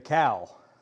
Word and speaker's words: KOW: And we also take something called the cow from here at the church. How many of you have heard of the cow before? Somebody, okay. It's spelled K-O-W, and it stands for Kitchen on Wheels.